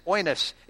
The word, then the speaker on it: oinos: And there was some confusion of how it's pronounced. And I went to the scholar himself. And yes, it's oinos in this sense.